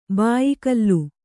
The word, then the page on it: ಭಯಾಕುಲೆ: bhayākule; Kannada